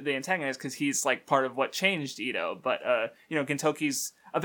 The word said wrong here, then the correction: Edo: Also, I notice you keep pronouncing it Ito. Wouldn't it be Edo? – Yeah, probably.